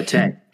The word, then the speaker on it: Kumite: I don't know if I'm saying that right. I just watched it 4 days ago and I already forgot. – Kumite. – Anyway. – Kumite. – Kumite. There we go.